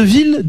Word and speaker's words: ville: Pour clôturer cette émission, Chris nous dit le son L de ville découle de l'étymologie, même son dans le latin villa.